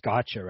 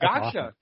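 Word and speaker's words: Gaxhja: I believe you said his name is pronounced Gotcha, right? – Yeah, you nailed that. Gotcha. – Because that's a fantastic last name. It's G-A-X-H-J-A for those of you at home.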